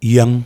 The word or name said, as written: Yeng